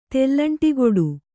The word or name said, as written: ತೆಲ್ಲಂಟಿಗೊಡು